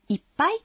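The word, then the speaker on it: いっぱい: «Иппай» содержит удваиваемый согласный звук. Когда вы произносите это слово, нужно на секунду задержать дыхание.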